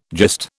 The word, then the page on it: gist: British English